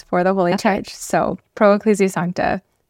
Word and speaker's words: Pro Ecclesia Sancta: You said it really fast, but it's Pro Ecclesia Sancta.